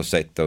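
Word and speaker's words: Ceuta: Qualcuno mi corregge sulla pronuncia di Theuta, citando, io ho degli ascoltatori oggi particolarmente, citando Dante, il canto dell'inferno, e mi lasciai alla man destra, mi lasciai Sibilia, dall'altra già mi aveva lasciato Setta, ossia Theuta, per essere più precisi.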